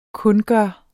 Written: kundgøre

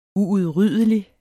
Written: uudryddelig

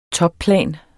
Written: topplan

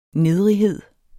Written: nedrighed

